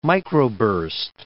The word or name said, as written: microburst